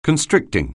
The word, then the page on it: constricting: anglais